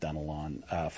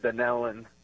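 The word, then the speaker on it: Dunnellon: I'm going to go with Dunelon, Florida, watching on YouTube. I hope I said the town right. I have not heard of that town before. – No, actually it's pronounced Dunelon.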